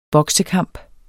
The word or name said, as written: boksekamp